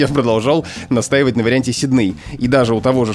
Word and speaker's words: Сидней: Это название австралийского города Сидней, которое, согласно словарям и в том числе словарю трудностей для работников СМИ, СМИ Михаила Абрамовича Штудинера произносится в образцовой речи именно так – Сидней. Но есть э, ряд людей, и это очень популярная тенденция э, говорить Сидней. Ну, потому что так э, говорят же в оригинале Сидней, в английском говорят Сидней.